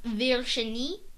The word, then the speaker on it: Virginie: It's actually supposed to be pronounced Virginie, but nobody cares.